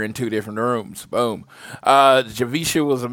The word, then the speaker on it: Javicia: I met Javika Leslie. I realized I was saying her name wrong for the long time. It's Javicia Leslie.